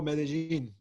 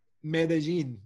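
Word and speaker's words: Medellín: You know, going back into some industry stuff, though, recently you just were part of the, forgive me if I say it wrong, it's the Medellin Techno Podcast. – Oh, Medellin. Yes. – Pronounce that. – Medellin. It's the city in Colombia.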